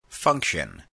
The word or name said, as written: function